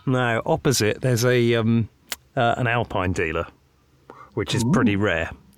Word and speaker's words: Alpine: That would be Alpine.